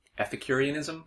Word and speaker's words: ethicurianism: since you don't like to talk about your food philosophy, your ethicurianism. – That's correct. – Am I saying that right? – That's right.